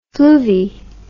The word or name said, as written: fluvi